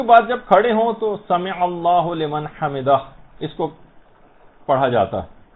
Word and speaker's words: سمع اللہ لمن حمدہ: عام طور پر لوگوں کو دیکھا گیا سمع اللہ لمن حمدہ یہ پڑھتے ہیں، اس کو ہولمن نہیں ہے، سمع اللہ لمن حمدہ، اس میں عین بھی